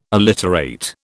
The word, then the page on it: alliterate: British English